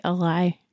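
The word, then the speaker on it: Alai: How do you say it? – I say Eli. – Yes. That's how they said it on audio too. Eli and Ender get chatting and they're working on figuring out what the weapons do.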